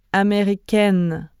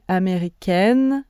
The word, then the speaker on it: américaine: And if you are a woman, you add most of the time an E at the end of the nationality, which slightly changes the pronunciation. So listen. Américain, américaine. Je suis américaine, je suis française, je suis anglaise.